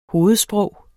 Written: hovedsprog

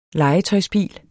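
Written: LEGETØJSBIL

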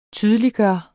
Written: TYDELIGGJORDES

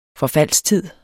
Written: forfaldstid

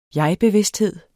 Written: jegbevidsthed

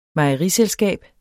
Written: mejeriselskab

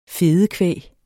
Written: fedekvæg

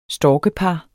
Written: storkepar

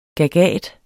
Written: gagat

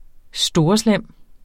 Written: storeslem

